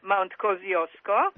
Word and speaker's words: Kosciuszko: Mount Kosciuszko, and you obviously pronounce it as Mount Kosciuszko.